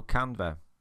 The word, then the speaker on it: Canva: So Canva, it's spelled C-A-N-V-A, like canvas but without the S.